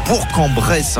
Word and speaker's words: Bourg-en-Bresse: On va se diriger tranquillement vers Bourg-en-Bresse parce qu'on dit Bourg-en-Bresse. Hein, on prononce bien le G.